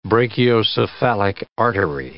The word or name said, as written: brachiocephalic artery